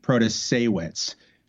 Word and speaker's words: Protasiewicz: And it's Janet Protasiewicz.